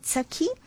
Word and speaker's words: సఖి: A quick recap for the word sakhi. Like I said, the pronunciation is a simple sa with an aspirated ka.